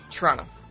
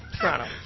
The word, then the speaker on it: Toronto: But somebody from Canada would pronounce it Toronto. Toronto.